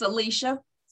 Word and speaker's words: Celicia: Is that Cecily? Is that how you say your name? C-C-E-L-I? How you say it? – It's Celicia.